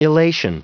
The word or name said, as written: elation